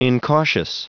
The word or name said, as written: incautious